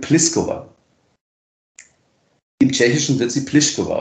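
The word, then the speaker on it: Plíšková: Also, die wissen natürlich, wie sie ausgesprochen werden in Tschechien, aber die einen sagen, ich will so ausgesprochen werden, wie es international üblich ist, Pliskova, und die anderen sagen, ich möchte so gemacht haben wie in meiner Landessprache, also Pliskova.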